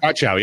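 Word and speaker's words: Godchaux: Is that how you say it? Godchow? Godchow? – Godchow, yeah. – Gotcha. All right. Got you. I got you. It's Godchow.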